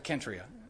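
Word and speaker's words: Cenchreae: What's funny is that Corinth and Centria start with the same letter. So it's actually Kentria. It's a K, but I'll say it like everybody does, Centria.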